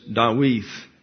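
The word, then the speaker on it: Dawith (דוד): So the second D in David does not contain the dagish lini, so it's pronounced like the diphthong TH as in the word thee. So it's Dawith.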